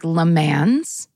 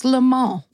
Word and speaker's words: Le Mans: So here in America, this word looks like Le Mans. Mm-hmm. It is French pronounced, obviously. It's a French word. And it's pronounced Le Mans.